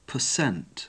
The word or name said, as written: per cent